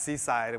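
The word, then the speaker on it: CCIED: the acronym would be pronounced Seaside and we all lived on the coast and that would be, no one gets that.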